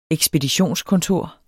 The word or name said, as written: ekspeditionskontor